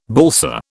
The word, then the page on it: balsa: British English